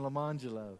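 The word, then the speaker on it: Lemonjello: They were spelled like this. Orange Jello and Lemon Jello, but it was pronounced Arangelo and Lamangelo.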